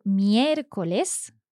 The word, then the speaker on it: miércoles: O, por ejemplo, miércoles. – Another is rújula, miércoles. Yes. So again, our accent sits on that first E in the word, miércoles.